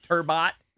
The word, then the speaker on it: Turbot: Apparently, the fish Turbot. – Yeah. – Somebody said it's Turbo.